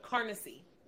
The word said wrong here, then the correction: Canarsie: And I'm saying that wrong. Canarsie. That's how you say it.